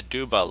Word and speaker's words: Aduba: I would say Aduba, like Aruba.